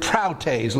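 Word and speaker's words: praütēs: And what it is, it's the Greek word proutes. Looks like this, P-R-A-U-T-E-S. Long E pronounced as an A. Proutes. And proutes, P-R-A-U-T-E-S.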